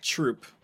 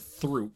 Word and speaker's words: Throop: Or even just troop. – Forgot about troop. – Because it's spelled throop. – Throop, yeah.